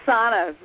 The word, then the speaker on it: Sona: It is Sana, very similar to Donna, but with that F, Sana Mehring.